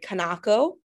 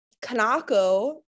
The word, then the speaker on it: Kanako: That's not how it's pronounced in Japanese, which is my heritage, which is where I'm from. In Japanese, it's Kanako.